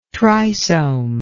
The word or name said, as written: trisome